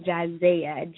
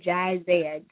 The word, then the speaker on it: Jizayah: It's actually Jaconia, but my name, where I can be reached on Facebook, is Jizaya. Jizaya, J-I-Z-A-Y-A-H, Jizaya Nicole.